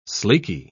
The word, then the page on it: sleeky: British English